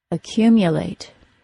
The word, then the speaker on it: accumulate: The mu syllable of the word accumulate sounds like mya, accumulate. The important thing here is that the long U sound, which is pronounced as U when it is stressed, is pronounced as ya when it is unstressed.